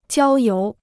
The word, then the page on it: 郊游: jiāo yóu